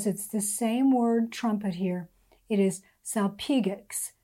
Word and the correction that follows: salpinx: In fact, I need to look up the pronunciation of that. Salpinx.